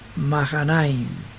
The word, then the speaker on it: Mahanaim: Y esta H unida a esta segunda A, pero que la precede una N con otra A, cambia de letra muda, que es la H, y se la pronuncia como J. Entonces es Mahanaim,